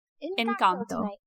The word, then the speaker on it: Encanto: How do you say? – Encanto.